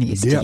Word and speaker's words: Dieppe: Uh, a village near Dieppe, which uh, was Dieppe, one, Dieppe.